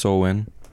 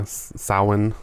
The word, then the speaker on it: Samhain: So the day is here, Halloween, and spelled Samhain, pronounced Sowen. yeah, Sowen. Sowen or something.